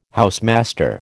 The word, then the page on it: housemaster: American English